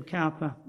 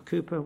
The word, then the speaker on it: Cowper: And as William Cowper, or Cooper, whatever way you want to pronounce it,